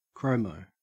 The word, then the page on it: chromo: Australian English